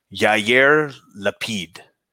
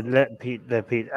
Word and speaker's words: Lapid: Yair Lapid. Is that right? Did I get it right? – Yeah, Lapid. I don't bother with their first names. It's Lapid, but the other guy is the more important one.